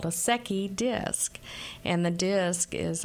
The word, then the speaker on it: Secchi: And Secchi is pronounced like Becky, S-E-C-C-H-I.